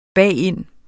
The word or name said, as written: bagind